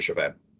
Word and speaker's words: Chauvin: is it Chauvin? The one that put his knee on the neck of George Floyd? – Officer Chauvin.